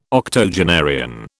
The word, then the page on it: octogenarian: British English